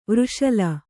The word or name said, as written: ವೃಷಲಿ